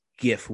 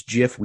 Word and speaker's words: gif: Folks, it is gif with a hard G. And if you think it's gif, we don't want you listening to the show.